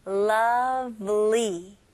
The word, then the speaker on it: lovely: 그래서 V는 입술이 붙으면 안 되겠죠? Lovely. 이음절입니다. Lovely.